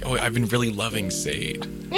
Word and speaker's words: Sade: When a particular friend of ours pronounced Sade Sade. – Oh my god, I yelled it. I heard it from like genuinely 10 feet away. My ear perked up. I was like, it's Sade. It's Sade.